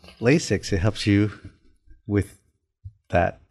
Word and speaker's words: LASIK: What is, am I saying it right? Is it LASIK? – LASIK.